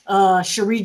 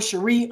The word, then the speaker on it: Sheri: Okay, so it's Sheri. I've been saying it wrong. Why didn't you tell me I'm saying it wrong? I mean, it's really a hyphen over one of those accents over the I.